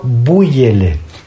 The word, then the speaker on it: búyele: Por un lado, búyele. – Sí, esa es la que yo conozco. – Pero con B, con B, con Y y con acento en la U.